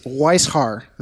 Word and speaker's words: Weishaar: And we have an extra special guest, a first timer on the Salty Dogs podcast, and Mr. James Weishar.